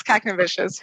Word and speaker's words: Kaknevicius: Jess Kaknevicius, VP Education with SFI.